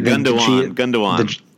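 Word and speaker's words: Gündoğan: Yeah, Gundogan. – The G is pronounced like a W.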